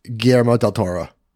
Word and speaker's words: Guillermo del Toro: So when Nightmare Alley came out, I was out because I'm not a big fan of Guillermo del Toro.